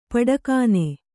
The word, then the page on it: ಪಟಕ್ಕನೆ: paṭakkane